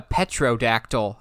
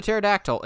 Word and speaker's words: pterodactyl: Somebody called a pterodactyl a petrodactyl, and I'm going, no, petrodactyl. I'm going, you do realize... The whole gimmick of the pterodactyl is the P is silent.